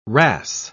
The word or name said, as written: wrasse